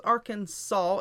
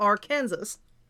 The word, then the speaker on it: Arkansas: While it is not technically illegal or punishable to say it wrong with a hard S at the end, Arkansas is legally supposed to be pronounced Arkansas and not Arkansas.